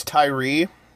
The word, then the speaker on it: Tyre: Tyre Nicholson? Is that how you would say his name? – I think it's Tyree. Tyree Nichols.